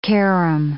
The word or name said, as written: carom